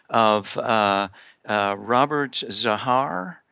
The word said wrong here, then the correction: Zagar: Getting his name right? – Zagar. – Thank you. Robert Zagar.